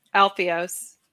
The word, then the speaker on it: Alpheos: Like you went in this story, the children of, how do you say, Apophis? – Alpheos. It's Alpheos. The river. It's the river there, the river valley. That's what it's named after, the city.